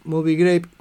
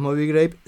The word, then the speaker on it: Moby Grape: Eh, suena lindo Movie Grape. Claro, o Movie Grape o Movie Grape. Es Movie Grape. Tengo matar, perdón si estoy diciéndolo mal, porque yo lo, lo pronuncio como si fuera con doble O.